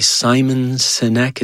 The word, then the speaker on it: Simon Sinek: Simon Sinek, is that how you say his name? – Mm-hmm.